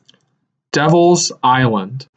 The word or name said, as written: Devil’s Island